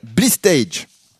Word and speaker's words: Bliss Stage: Alors, aujourd'hui, le jeu qu'on vous propose, c'est Blistage de Ben Lehman.